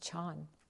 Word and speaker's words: Chan: We look at Tang Dynasty China when Zen was in its most glorified, highest, the pinnacle of Chan Zen in China, pronounced Chan.